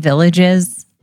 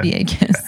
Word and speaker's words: Villegas: Do people say Tim Villages? – They, uh, I've heard my name mispronounced lots of times, but it's Vegas. Okay. Yeah. Vegas.